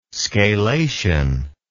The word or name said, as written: scalation